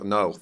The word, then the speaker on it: Noth: Noth. – Rhymes with birth, not moth.